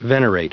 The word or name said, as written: venerate